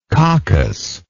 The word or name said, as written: coccous